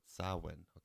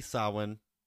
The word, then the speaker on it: Samhain: Samhain. You, like, broke my mind. – It's like saw and win put together.